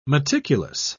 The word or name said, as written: meticulous